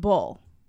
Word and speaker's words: boul: Like B-O-O-L? – I'm sorry, my handwriting is not great. It is young, B-O-U-L. – Oh, U-L. – Pronounced bull. Young bull.